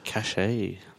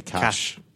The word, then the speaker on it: cache: Oh, a cache. – So you say cache, I say cash. – That's because I'm fancy. – Oh. – Yes. He pronounces the E.